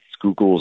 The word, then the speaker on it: Schuylkill: I love running down the Schuylkill River here, which is great. Schuylkill is the one that doesn't look like it's spelled like Schuylkill, but that's how you say it.